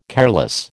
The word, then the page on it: careless: American English